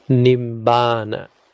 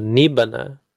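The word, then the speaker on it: nibbāna: Penultimate syllable is long, so stress goes there. Nibbana. You want to say it's not Nibbana, it's Nibbana. It's Nibbana. That's all. That's stress.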